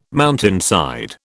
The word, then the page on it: mountainside: British English